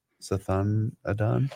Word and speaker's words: Sathanadan: Sathanadan? – Yep.